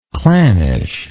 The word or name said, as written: clannishness